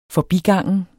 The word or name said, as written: forbigangen